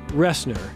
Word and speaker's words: Roessner: Ressner. But it is spelled R-O-E-S-S-N-E-R, and that's from 2014, TEDx Flower City.